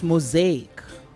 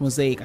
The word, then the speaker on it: mosaic: And then it's mosaic. The word is pronounced mosaic. I can't remember how you said it, but it's mosaic. – Yeah, the temptation is to say mosaic. – Yes, mosaic, yes. So it's mosaic. And then we've got categorize, yeah.